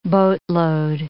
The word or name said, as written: boatload